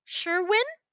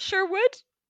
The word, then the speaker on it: Sherwin: We're like 15 minutes away from the end of the movie when they suddenly change the pronunciation of the horse's name. And now it's Sherwin, not Sherwood.